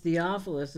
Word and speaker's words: Theophilus: You know, the reason why I know it's pronounced Theophilus